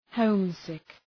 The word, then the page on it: homesick: ‘həʋm,sık